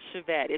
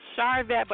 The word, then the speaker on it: Charvette: You say it like my mother says it. My mother says Charvette. It's Charvette, but you know what? My mother says Charvette.